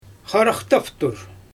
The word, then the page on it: xaraxtaftur: Xyzyl